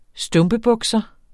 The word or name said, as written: stumpebukser